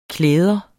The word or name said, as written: klæde